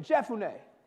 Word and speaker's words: Jephunneh: Jephunneh. Jephun. That PH is an F. You know, English is weird. They could have just put an F in there. Would have been so much easier. Jephunneh.